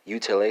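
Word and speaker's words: utilisés: It's going to be gebruikt, spelled G-E-B-R-U-I-K-T. French speakers translate the Dutch to the French. This is going to be utile, spelled U-T-I-L-I-S-E-S. That S is silent, that last S.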